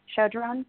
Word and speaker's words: Chodron: Chodron is that how you say her name? Chodron.